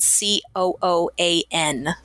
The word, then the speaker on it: Cooan: Last name is spelled C O O A N.